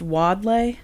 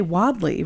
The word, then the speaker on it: Wadleigh: Which you think is still pronounced Wadley? – I don't know. W-A-D-L-E-I-G-H. It's still around. – Well, it's probably Wadley, right? – Probably. That sounds way better.